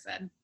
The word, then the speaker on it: Erised: Yeah, Mirror of Erised.